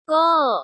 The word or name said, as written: go